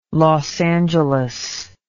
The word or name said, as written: los angeles